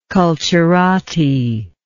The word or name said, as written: culturati